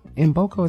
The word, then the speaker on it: Mboko: Mboko is how you say it. Victoria Mboko is M. It's K-M-B-O-K-O.